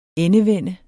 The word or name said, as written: ENDEVENDES